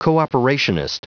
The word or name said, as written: cooperationist